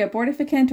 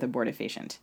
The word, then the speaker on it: abortifacient: Silphium isn't the only, and I'm actually not sure I know how to say it, abortifacient? Am I saying it right? – I've heard people say abortificant or abortifacient. – Okay, I guess I'm going to go with abortifacient.